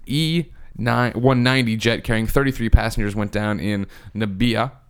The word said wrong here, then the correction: Namibia: Oh, Namibia. – Namibia.